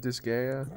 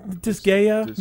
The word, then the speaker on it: Disgaea: Isn't it Disgaea? – Disgaea.